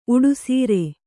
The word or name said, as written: ಉಡುಸೀರೆ